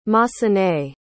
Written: massenet

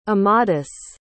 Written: amadis

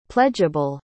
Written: pledgeable

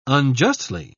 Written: unjustly

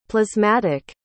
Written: plasmatic

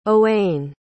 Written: owain

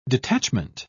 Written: detachment

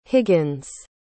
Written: higgins

